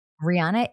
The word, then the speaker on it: Rihanna: Rihanna is a billionaire. She has reached billionaire status thanks to Fenty Beauty. – Wait, I think it's pronounced Rihanna.